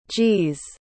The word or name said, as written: g's